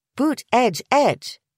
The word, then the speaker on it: Buttigieg: Using three words to demonstrate how it's supposed to sound gives the impression that all three syllables should be emphasized equally. Buttigieg. But the middle syllable is more of a throwaway, an unstressed vowel or schwa, as it's called in the International Phonetic Alphabet.